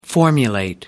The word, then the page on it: formulate: American English